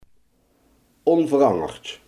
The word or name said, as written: onveranderd